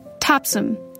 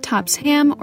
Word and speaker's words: Topsham: Topsom. Not Topsham or Topsham. Topsom. The second syllable is clipped, so it almost sounds like there's no vowel.